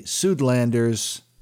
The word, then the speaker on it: Suidlanders: And again, Simon Roche with the Suitlanders, and you can check out his website online. But anyway, and Suitlanders, by the way, is spelled Suidlanders.